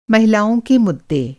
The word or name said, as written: मुद्दा